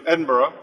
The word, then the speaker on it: Edinburgh: I'm on the bus from Newcastle to Edinburgh. Is that how you say it? Yeah, well done.